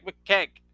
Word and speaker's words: McKeag: Greg McKeag. It rhymes. Greg McKeag.